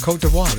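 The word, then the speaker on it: Côte d'Ivoire: I didn't know how to say it, but that's right. Cote d'Ivoire, they're out of West Africa.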